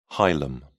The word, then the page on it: hilum: British English